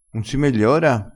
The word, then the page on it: megliora: dialettale